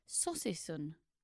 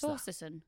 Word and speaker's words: saucisson: He replied, Saucy son. I don't know. It's spelt SAUCISSON. – What is Saucy son.